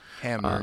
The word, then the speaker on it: Hamner: Hamner, I think, is how you say it? – Hamner, yeah.